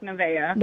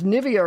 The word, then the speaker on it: Nevaeh: Yeah, it's spelled, heaven spelled backwards. – Oh, great. – It's a trend. – Oh. – Pronounced Nevaeh. – Nevaeh. All right. See, I would have looked at that and said, thought it was Nivea or something.